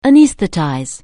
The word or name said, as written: anaesthetize